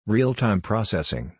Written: Real-Time Processing

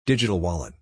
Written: Digital Wallet

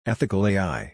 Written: Ethical AI

